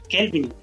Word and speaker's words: Kelvin: llamé Kevin van der Linde, cuando es Kelvin. Pues yo ni me había dado cuenta, si no, en cabina se lo había comentado yo, no, que te falta la por pronunciar la L.